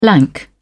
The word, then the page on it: lank: British English